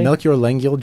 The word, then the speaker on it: Melchior Lengyel: Melchior Lengyel joint? – Lengyel joint, yeah.